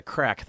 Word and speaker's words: craic: When we come back, we're going to have the Crack. That's spelled C-R-A-I-C, which is a authentically Irish band.